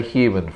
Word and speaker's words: human: Would you say human for our guests? – Human. – That's very good. You cheated. Yeah. That's what I say. You usually human. Don't pronounce the h.